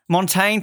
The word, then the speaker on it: Montaigne: Is it Montagne? Am I saying that correctly? No. – Montaigne, thank you. You're out of the country for how long and you can't pronounce a simple word?